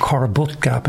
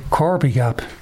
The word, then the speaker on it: Corby Gap: Now, on the Ordnance Survey maps, that's called Corbutt Gap. And you see it in all the signs. But Corby Gap. Corby Gap is definitely the local pronunciation for that.